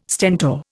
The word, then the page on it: Stentor: Indian